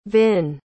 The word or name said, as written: vinn